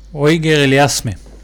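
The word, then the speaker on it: Auger-Aliassime: אה, ניצח בצורה מאוד יפה, אפשר להגיד את אה, אוגור אוליאסים, ככה מבטאים את הקנדי? אויגר אליאסמה.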